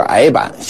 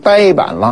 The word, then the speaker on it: 呆板: 这个呆板过去我们都说是矮板，现在读音都改了，统一成呆板了。